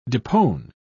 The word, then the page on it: depone: British English